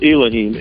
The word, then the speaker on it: Elohim: I pronounce it Elohim, E-L-O-H-I-M.